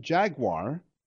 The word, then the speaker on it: jaguar: Jaguar, the name jaguar, or jaguar as some people pronounce it, comes from the Native American word meaning he who kills with one leap.